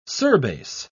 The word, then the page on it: surbase: British English